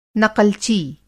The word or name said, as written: नक़लची